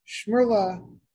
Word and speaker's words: Szmerla: Shmurla, I think, is the way it's pronounced in English. It's spelled S-Z-M-E-R-L-A.